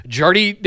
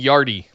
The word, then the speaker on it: Jardy: With the hard J. No, you were right. I was saying Yardy. – I was saying Yardy. Whatever. It's Jardy.